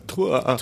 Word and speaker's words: Tours: I went to this town above Paris called Tours, and I'm really butchering the pronunciation of that, but it's where all these old castles are. And I think it's pronounced Tours.